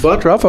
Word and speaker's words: Butruff: I'm here with Sarah Buttruff, right? – Yep, Buttruff. – Buttruff, okay, I'll say it right.